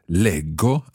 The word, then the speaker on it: leggo: Because in both those parts, the j of the infinitive, leggere, becomes a hard g sound. Leggo, I read. Leggono. They read. Otherwise, it's always G. Leggi, legge, leggiamo, leggete. But leggo and leggono.